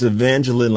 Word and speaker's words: Evangeline: It's Evangeline, like Jacqueline.